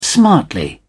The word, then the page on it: smartly: British English